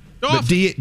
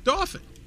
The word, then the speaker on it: Dauphin: Or is it D-A, like Dolphin? – Dolphin. – D-A-U-P-H-I-N. – Yeah, Dolphin.